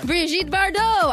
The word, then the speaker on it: Brigitte Bardot: Brigitte Bardot.